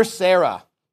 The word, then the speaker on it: Serra: Father Sarah. Okay, and it's spelled S-E-R-R-A, okay?